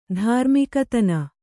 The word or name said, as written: ಧಾರ್ಮಿಕತನ